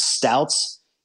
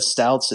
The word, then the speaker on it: Stoudts: There's a Marizen German-style brewery in Reading called Stouts. It's Stoudts, something like that. Stouts. It's not stout, like Stout. It's spelled differently.